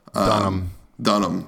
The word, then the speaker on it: Dunham: Is that how you say her name? The girl star, Lena Dunham? – Dunham. D-U-N.